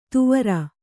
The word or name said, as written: ತುವರ